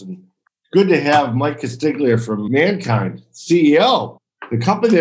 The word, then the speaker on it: Castagna: Castagna, lasagna.